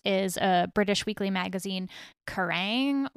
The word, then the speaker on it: Kerrang: Oh, yeah. I've always called it Kerrang.